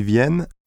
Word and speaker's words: viennent: In the third person plural form ils viennent, however, the presence of double N denasalizes the vowel into E, as in les, and the N is pronounced.